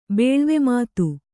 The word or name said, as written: ಬೇಳ್ವೆಮಾತು